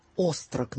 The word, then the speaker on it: Острог: И оттуда мы поехали в монастырь Острог называется. Именно так ударение.